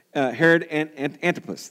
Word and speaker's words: Herod Antipas: Herod Antipas, that's how you say it.